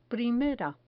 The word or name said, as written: primera